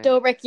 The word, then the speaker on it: Dobrik: Dobrik, yeah.